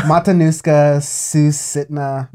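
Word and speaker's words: Matanuska-Susitna: Portugal the man has the there's a borough of Matanuska Sistina, something like that, in Alaska. – I said, what are you trying to pronounce? Some random province in Canada? – Matanuska Susitna.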